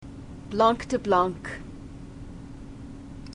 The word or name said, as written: blanc de blancs